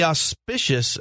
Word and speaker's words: auspices: under the auspices, is that how that word is pronounced, of a vacation. Yes.